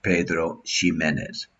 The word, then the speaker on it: Pedro Ximénez: And the other is the legendary Pedro Ximénez, which is now often spelled with an X. So it often sounds more like Pedro Ximénez.